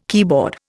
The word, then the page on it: keyboard: Indian